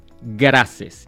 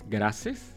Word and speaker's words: Graces: Mi nombre es Andrés Graces. Y voy a decir cómo se escribe gracias. Como Guatemala, Río, América, Santiago, Ecuador, Santiago. Y lo digo porque si escriben en Google, en Google, Andrés Graces, yo soy el único Andrés Gracias del mundo mundial.